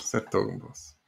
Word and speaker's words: Hertogenbosch: Yes, stage two of this year's Vuelta is 175.1K from, I'm probably going to screw this up, but Hertogenbosch back to Utrecht where they started today. Did I say that correctly? – Yeah, more or less.